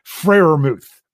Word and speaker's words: Freiermuth: but Pratt Freiermuth.